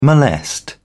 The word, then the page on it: molest: British English